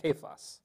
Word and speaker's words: Cephas: And I looked it up, it's pronounced Cephas.